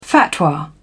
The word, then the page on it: fatwa: British English